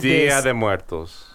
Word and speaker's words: Día de Muertos: Día de los Muertos. – Día de Muertos.